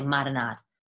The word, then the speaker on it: marinara: Well, for starters, I would call it a marinade. But I know it's spelled marinara. But Italian Americans say marinade. Marinade.